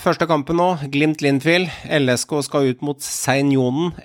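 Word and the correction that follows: Seinäjoki: FK, rett gjerne på meg hvis dere uttaler det andre. Seinjonen. – Senajoki. – Senajoki FK, og så skal Viking ut mot Sparta Praha, og så skal Molde ut mot Elfsborg.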